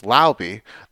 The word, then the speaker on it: Laube: and Dylan Lauby, who I now realize should be pronounced Lauby, the running back from New Hampshire.